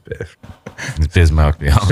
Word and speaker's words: Bismack Biyombo: I mean, yeah, he's Bismack Biyombo. – Bismack Biyombo.